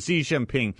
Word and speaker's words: Xi Jinping: How do you say it? – Yeah, there you go. – Yeah, I reversed it. Xi Jinping.